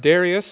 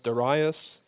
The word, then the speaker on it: Darius: The first year of Darius, or you'll sometimes hear that pronounced Darius, the Mede.